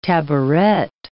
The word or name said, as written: taboret